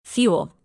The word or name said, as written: fuel